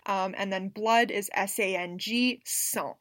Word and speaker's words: sang: because like the word S-A-N-G, you would not expect that to be pronounced sans. Like, you don't hear the G at all.